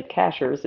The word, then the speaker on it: Cashiers: It's spelled C-A-S-H-I-E-R-S, but people say Cashers. It's not Cashiers.